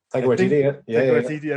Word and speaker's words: Taika Waititi: I'm going to butcher his name. Taika Waititi. – Taika Waititi. – I think that's how you pronounce it.